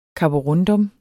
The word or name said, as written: karborundum